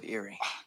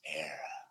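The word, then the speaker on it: Éire: I said the shores of Erie. – Would you at least learn how to say it? The land of your fathers isn't Erie, Boyle. It's Era.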